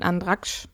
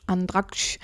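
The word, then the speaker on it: Andratx: Vorletztes Jahr, 2019 war das, in Andraksch. Habe ich es richtig ausgesprochen? Andraksch, genau. Und da war ich tatsächlich einfach nicht Pauschalurlaub, aber in einem Hotel und habe auch da Mallorca kennengelernt.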